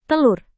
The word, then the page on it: telur: indonesian